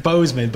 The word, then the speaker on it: Boozman: Or I think he's Boozman, but it's spelled B-O-O-Z-M-A-N.